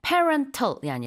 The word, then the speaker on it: parental: 뒤에 al 붙이면은 발음이 parental이 아니라 parental 이렇게 바뀝니다. 그래서 parental, 그다음에 leave가 이게 바로 휴직이에요.